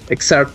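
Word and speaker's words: Xrd: ¿Cómo, ¿Cómo se pronuncia? ¿X, ¿XRS o XRS? Exart.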